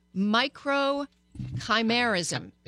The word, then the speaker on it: microchimerism: And what they found is that the influence of this it's actually called, let me see if I can say it right. Joey, do you remember? Micro chimerism. – How'd I do? – Perfect. The association between male microchimerism and the female brain and relative health versus disease.